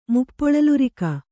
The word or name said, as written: ಮುಪ್ಪೊಳಲುರಿಕ